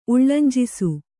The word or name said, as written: ಉಳ್ಳಂಜಿಸು